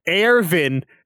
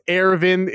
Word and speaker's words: Erwin: I'm sorry for butchering our man, Ervin. Don't know why they did this. It's spelled Erwin. It's pronounced Ervin.